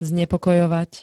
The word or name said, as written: znepokojovať